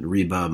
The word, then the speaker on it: Riba: I'm going to mispronounce them, right? Riba, Maisir, and Garar. Yeah. Did I pronounce that properly? – Yeah.